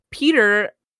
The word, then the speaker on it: Pyotr: He ordered additional trucks to be sent out to Kaptiaki whilst assigning Peter. Oh, I'm surprised I said that right, because it's spelled P-Y-O-T-R.